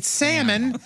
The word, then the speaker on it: salmon: Is it salmon or salmon? – It's salmon. Salmon. Who's pronouncing the L? – We're getting silly now, right?